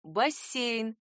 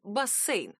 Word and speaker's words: бассейн: Литературная норма «бассейн» с мягким звуком «с». Младшее допустимое «бассейн».